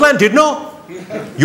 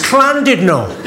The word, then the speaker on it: Llandudno: This guy, front and centre, where you're sitting there, face like fucking thunder, went, it's not Llandudno, you bloody fool. It's Clandudno. In Wales, the double L is pronounced with a C.